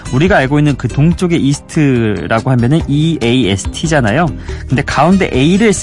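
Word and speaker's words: E^ST: 그래서 E 웃음 표시 하나, 그다음에 ST 이렇게 해서 이스트입니다. 읽을 때는 그냥 이스트라고 읽는데요.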